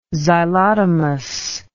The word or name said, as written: xylotomous